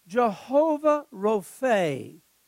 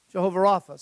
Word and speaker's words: Jehovah Rapha: Jehovah Rophe, Jehovah Rapha, some pronounce it.